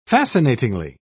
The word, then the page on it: fascinatingly: British English